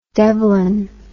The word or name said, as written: devlin